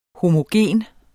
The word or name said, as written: homogen